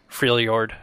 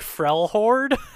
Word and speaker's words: Freljord: Freljord. Okay, yeah. I never pronounce that correctly. Even in my mind when I'm reading it, I'm just like, Frelhord? – No! Yeah, it's based off of like Nordic and Slavic languages.